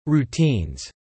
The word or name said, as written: routines